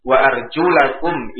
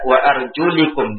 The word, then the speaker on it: wa arjulakum: Wa arjulakum ilal ka'bain, ada qiraah syadzah yang bacanya dengan wa arjulikum, dengan kasrah ya, wa arjulakum, kemudian dibaca wa arjulikum ya, sebagaimana yang dipegang oleh orang-orang Syiah.